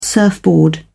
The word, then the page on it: surfboard: British English